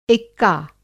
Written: एक्का

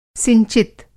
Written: सिंचित

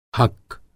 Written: हक्क

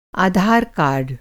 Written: आधार कार्ड

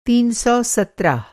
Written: तीन सौ सत्रह

